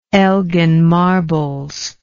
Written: elgin marbles

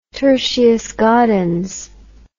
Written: tertius gaudens

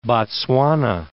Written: Botswana

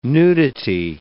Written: nudity

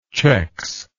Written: chex

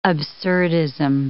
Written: absurdism

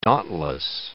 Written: dauntless

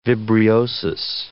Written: vibriosis

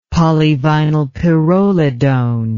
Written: polyvinylpyrrolidone

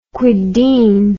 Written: kwedien

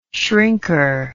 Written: shrinker

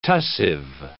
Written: tussive

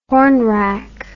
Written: hornwrack

